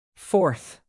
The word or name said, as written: fourth